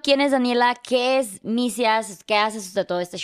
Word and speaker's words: Misias: De hecho, la cuenta Misia, ¿es Misias o misías. Misias, Misias.